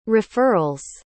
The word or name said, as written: referrals